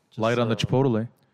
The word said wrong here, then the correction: Chipotle: You pronounced every letter of Chipotle, man. Chipotle. Oh, man.